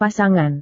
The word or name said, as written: pasangan